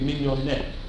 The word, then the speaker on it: Mignonette: I think I give it wrong, there's Mignot, that's a mistake. It should be Mignonette. There's an extra syllable there.